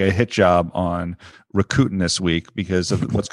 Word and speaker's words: Rakuten: We've got, I'm going to say it wrong, Rakuten. – Sorry, Rakuten. I've been practicing and I still did it wrong. – Rakuten.